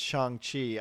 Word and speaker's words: Shang-Chi: But yes, apparently it's pronounced Shang-Chi.